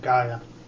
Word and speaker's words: Gaia: Where some folks talk about people worshiping Gaia. Do I pronounce that right? Gaia? Worshiping the earth. – Oh, Gaia. – Gaia.